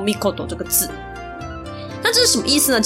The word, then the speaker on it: 命: 好，刚刚他爸爸的名字叫做天忍岁尔命，然后儿子呢叫做藩能尔尔义命，他后面都有一个命字，所以呢这边我想要稍微岔出来补充一下，命在日文里面有很多读音，那这个加在名字后面的，它念作 mikoto。